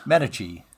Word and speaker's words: Medici: Medici.